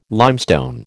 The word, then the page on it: limestone: American English